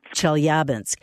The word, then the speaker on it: Chelyabinsk: Chelyabinsk, am I pronouncing that correct? – Chelyabinsk was an important industrial city during World War II and the Cold War.